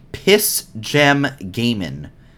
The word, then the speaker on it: Piss Gem Gaming: Eastern time, from what I understand, versus, I am not pronouncing this incorrectly, it is Piss Gem Gaming. P-I-S-S space G-E-M space Gaming. That's gaming with no G at the end.